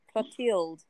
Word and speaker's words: Clotilde: Clotilde, C L O T I L D E, Clotilde.